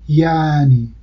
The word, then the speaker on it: یعنی: میگم یعنی، نمیگم یعنی، میگم یعنی. یعنی خب این صدای چاکنایی تلفظ نمیشه و به جاش مصوتش طولانی تر میشه یعنی.